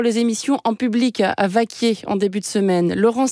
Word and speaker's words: Vacquiers: Au fait, Vaquier, ça s'écrit V-A-C-Q-U-I-E-R-S. Vaquier.